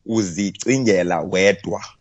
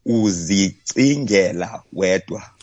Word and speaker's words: uzicingela wedwa: Okay, so it's a Xhosa phrase for this week, or today rather, is called, uzicingela wedwa. Uzicingela wedwa. Try with me. – Uzicingela wedwa. – Uzicingela wedwa. Yes. Perfect, Lester. You spell it UZICINGELA. Uzicingela. Wedwa. WEDWA. Right.